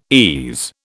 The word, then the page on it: ease: British English